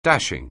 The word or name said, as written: dashing